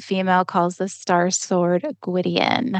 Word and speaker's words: Gwydion: Is that how you pronounce it? – I think so. I think that's how I pronounced it. I feel like my brain changes it on me every now and again. That sounded right. – Gwydion. Gwydion. – Yeah, first one's good.